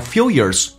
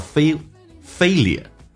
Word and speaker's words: failure: Next one， and failures are worth remembering. – English 发音这个单词叫 failure，那么美式发音把 L 提前发叫 failure， right？往前面发就好像 million。 English 发音美式发音把 L 放前面 million，一样道理叫做 failure。